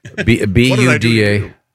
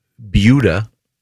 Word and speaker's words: Buda: No, that's how it's pronounced. – Okay. – Well, Jason, can you get someone on the phone from Beulah, Texas, please? – Yeah, B-U-D-A. Missed the letter segment. Maybe you could do some of those for him. – Beulah. Beulah, Texas. They have a gigantic dachshund, wiener dog, which I think is cool. – It is cool. – And we were learning how to pronounce Beulah.